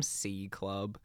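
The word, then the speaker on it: CVNT CVLB: I'm going to call them C Club. It's spelled C-V-N-T, C-V-L-B. I'm going to say C Club with the new single called LARP featuring another Melbourne rapper, Adnam.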